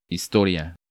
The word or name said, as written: Historia